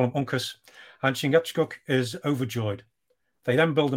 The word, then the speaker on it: Chingachgook: Chingachgook? – Chingachgook.